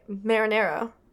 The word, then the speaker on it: Marinaro: First of all, that looks like marinara. I think it's Marinaro. – Marinaro. Marinaro. – It's spelled like marinara, except for without a E at the end, or A at the end.